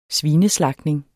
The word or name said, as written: svineslagtning